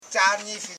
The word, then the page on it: chanifi: cha.ni.fi